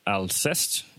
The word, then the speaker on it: Alcest: And uh, today we have a guest from the band Alcest. Is that correct to pronounce? Yes, it's yeah, yeah.